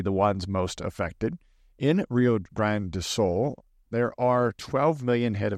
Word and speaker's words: Rio Grande do Sul: The floods in the Brazilian state of Rio Grande do Sul. Did I say that right this time? – You said that right. Good job.